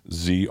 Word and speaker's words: Zrinjski: So again, the pronunciation, bear with me. It's Zrinjski. And